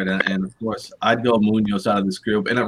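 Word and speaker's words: Muñoz: At least pronounce it right if you're going to take my guy. Sebastian Munoz. – I just can't do the rolling in.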